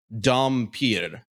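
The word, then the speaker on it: dhampir: It is pronounced Dampier. – Dampier. – Dampier. – Yeah. – That's its original Serbian pronunciation.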